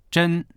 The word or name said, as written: zhen1